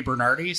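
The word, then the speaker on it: Bernardy: It's my name, Cody Bernardi. So it's spelled like Saint Bernard with a Y at the end.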